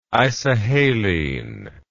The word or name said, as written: isohaline